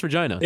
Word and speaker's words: Regina: I thought it was Regina. – No, it's pronounced Regina. – Is it really? – Regina. It's Regina.